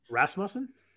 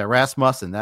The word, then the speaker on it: Rasmussen: Uh, I'm very, I can't, for re- reason, I can't pronounce his name, even though Isn't it's a very simple, common name. Rasmussen? – Yeah, Rasmussen. I guess that's it. If I go must instead of Muse, then I'm good.